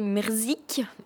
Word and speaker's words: Mrzyk: Donc, c'est Merzik et Morisseau. Merzik, je vous précise quand même que ça s'écrit M-R-Z-Y-K, donc impossible à prononcer.